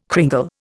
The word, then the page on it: cringle: Indian